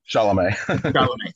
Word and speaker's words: Chalamet: or however you pronounce his name. – Chalamet. – Thank you.